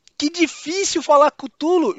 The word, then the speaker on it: Cthulhu: Em inglês é Cutulo. Cara, os caras ficam.